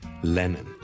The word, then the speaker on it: Lennon: 第一個字呢就是人名來的， John Lennon， John Lennon， John Lennon，一個英國的很出名的歌手和作曲家， Lennon， L-E-N-N-O-N，第一個是 L 音，所以不要將它讀成 N 音，不是 Lennon，是 Lennon， Len LeLeLeLeLeLeLeLeLeLeLeLeLeLeLeLeLeLeLeLeLeLeLeLeLeLeLeLeLeLeLeLeLeLeLeLeLeLeLeLeLeLeLeLeLeLeLeLeLeLeLeLeLeLeLeLeLeLeLeLeLeLeLeLeLeLeLeLeLeLeLeLeLeLeLeLeLeLeLeLeLeLeLeLeLeLeLeLeLeLeLeLeLeLeLeLeLeLeLeLeLeLeLeLeLeLeLeLeLeLeLeLeLeLeLeLeLeLeLeLeLeLeLeLeLeLeLeLeLeLeLeLeLeLeLeLeLeLeLeLeLeLeLeLeLeLeLeLeLeLeLeLeLeLeLeLeLeLeLeLeLeLeLeLeLeLeLeLeLeLeLeLeLeLeLeLeLeLeLeLeLeLeLeLeLeLeLeLeLeLeLeLe Lenin 第一個 L， Lenin 所以 John Lennon 大家練一練 John 當然是 N 音味啦，又更加搞亂啦。